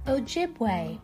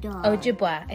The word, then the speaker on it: Ojibwe: He has especially enjoyed the November series for Indigenous Peoples Month since his mother is half Ojibwe. Ojibwa. Ojibwa, I think is how you say it.